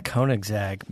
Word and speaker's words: Koenigsegg: Yeah, Koenigsegg.